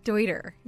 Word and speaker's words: Deuter: Deuter? That's how it's pronounced? – Yeah, Deuter, named after the founder, Hans Deuter.